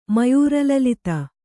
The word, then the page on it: ಮಯೂರಲಲಿತ: mayūra lalita